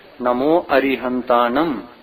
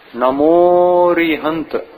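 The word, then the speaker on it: नमो अरिहंताणं: नमो अरिहंतानम नमोकार मंत्र में तो अ का भी उच्चारण अपन करते हैं कि नहीं अलग से, नमो अरिहंतानम फिर लोप कहाँ हुआ, किंतु संस्कृत भाषा में अगर लिखेंगे नमो अरिहंत नमोरिहंत ऐसा पढ़ेंगे, अ को छोड़कर पढ़ेंगे नमोरिहंत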